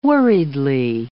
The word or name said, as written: worriedly